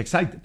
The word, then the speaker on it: Exide: Dentro se escribe Exide, se pronuncia Exide.